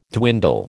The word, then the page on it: dwindle: American English